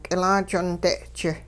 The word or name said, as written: k'ilaajonde'-ji